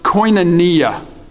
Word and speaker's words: koinonia: And this word fellowship translated in the Greek is a word that's pronounced koinonia.